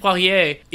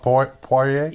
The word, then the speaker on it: Poirier: And speaking of about that same amount of money, another one that, that came about was I'm, I'm going to use the French pronunciation because I'm me. Vincent Poirier is getting. You mean Vincent Poirier? – Yeah, I guess we go Vincent.